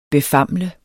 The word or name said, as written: befamle